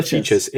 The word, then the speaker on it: Fejes: Hunter Features. F-E-J-E-S.